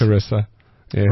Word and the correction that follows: Carissa: Right. It's Carissa. I've been saying it wrong the whole time. – Carissa.